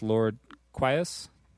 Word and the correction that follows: Lord Quas: Lord Quas? – Lord Quas.